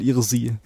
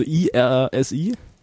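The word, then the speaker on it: irssi: dann sage ich jetzt mal Irsi. Also IRSI. – Klingt wie Irsi. – Irsi, genau, Irsi. Man kann es auch IRC oder was auch immer aussprechen. Ich weiß nicht, wie die konkrete Aussprache ist.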